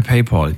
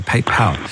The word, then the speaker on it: PayPal: Ich schicke dir per PayPal. Jens, vielen Dank. – PayPal, gute Idee. – PayPal, sorry, ich vergesse jedes Mal, dass ich es falsch ausspreche. PayPal.